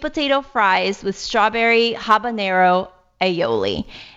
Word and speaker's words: aioli: Aioli. – Aioli.